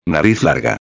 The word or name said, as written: larga